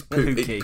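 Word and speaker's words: Pukki: Have I pronounced that right, yeah? – Pookie. no, you haven't. It's Pookie. Pookie.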